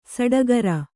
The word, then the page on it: ಸುಟಿಕರ: kannada